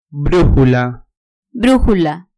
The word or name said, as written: brujula